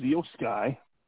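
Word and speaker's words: Iyo Sky: We then get Bianca Belair versus Io Sky.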